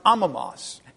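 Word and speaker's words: amamos (ἄμωμος): It's A-M-O-M-O-S in the English, but it's pronounced amamos, and that's the Greek for it.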